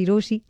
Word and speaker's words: Rochie: मेरा नाम है मिर्ची रोशी, नाम की स्पेलिंग है ROCHIE, और मेरे साथ लाइन पर आयुष है.